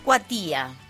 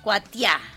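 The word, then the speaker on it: Curuzú Cuatiá: Bueno, escuchábamos Cabo Rin y Curuzu Cuatía. – Cuatía. – ¿Sí? Cuatía. Uh-huh. Ahí está. Bueno, yo le estoy poniendo el acento en cualquier lado.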